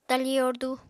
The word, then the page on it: tali yordu: apatani